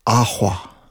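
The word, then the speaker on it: Ahwa (قهوة): And so it's in the province of Kaffa, K-A-F-F-A, but it's an Arabic word, really, which means a drink from berries, and it's spelled Q-U-A-H-W-A-H, and I'm going to try not to slaughter their pronunciation, but it's like, it's Ahwa, ahwa.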